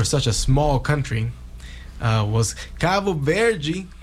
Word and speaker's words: Cabo Verde: I mean, Cabo Verde would be the proper way to say it in Portuguese. – Yeah. – And I think, you know, with a slight accent difference, that would be the correct way to say it in Crioulo also.